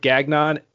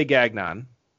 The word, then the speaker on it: Gagnon: So moving into the first, Jacob Gagnon, and I will say Gagnon, because I know how it's pronounced. No, I know. Yeah. But that's how it's pronounced in Canada.